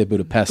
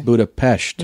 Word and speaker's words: Budapest: Oh, so it's just the Esh part is what people screw up. They say Budapest. – Budapest here. – Is the English pronunciation. – But it's Budapest. – Because an S in Hungarian is a sh sound.